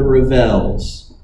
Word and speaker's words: Ravels: By a doo-wop quintet called the Ravels, accent on the second syllable.